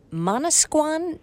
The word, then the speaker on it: Manasquan: Let's turn to Mary in Manasquan, New Jersey.